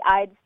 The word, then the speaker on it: Ihde: It's just my first and last name, except while my last name is spelled I-H-D-E, my studio is called Carly Eyed Studio, and Eyed is spelled E-Y-E-D, so that people know how to say it right.